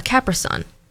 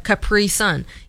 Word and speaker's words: Capri Sun: So in Ashley's speech, I pronounce it the same way you would pronounce a Capricorn. So a Capri Sun, you know, like Capricorn, Capri Sun, instead of Capri Sun.